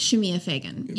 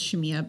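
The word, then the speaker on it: Shemia Fagan: Shemaiah Fagan, yes. – Yeah, Shemaiah Fagan. – I think it's pronounced Shemaiah, but it probably is.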